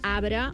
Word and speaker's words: arbre: Que diem, molt sovint diem, no la pronunciem la R, i diem arbre i mabre.